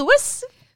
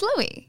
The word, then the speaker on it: Louis: I mean, when you ask a question that absolute, of course there's someone whose name spelled L-O-U-I-S that could be pronounced Lewis. But my understanding is that it's Louis.